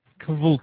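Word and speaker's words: kvlt: Kvolt. – Yeah, because it's spelled with a V, so it's Kvolt.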